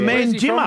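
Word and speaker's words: Manjimup: Well done from Man Jimmer. From Mandra. – No, Man Jimmer, Jim. – Oh, Man Jimmer. Oh, yeah, beautiful. – Yeah. – Man Jimmer. – Oh, well, it's Man three Jimmer. Syllables.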